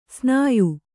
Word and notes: ಸ್ನಾಯು: snāyu

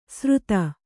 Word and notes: ಸ್ರುತ: sruta

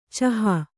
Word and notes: ಚೋಹ: cōha

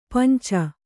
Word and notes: ಪಂಚ: kannada

